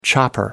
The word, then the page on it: chopper: American English